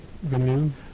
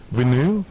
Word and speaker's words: Vinyl: Wie spricht man das aus im Englischen? – Vinyl. – Vinyl.